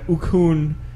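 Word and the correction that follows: Oconee: It's Akani. You fucking said it wrong.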